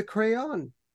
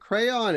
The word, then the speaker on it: Craon: Um, he's raised by his maternal grandfather, uh, John de Crayon. And uh, it's pronounced like crayon, as far as I can tell.